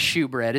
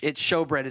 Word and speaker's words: showbread: It looks like shewbread. It's showbread is how it's pronounced.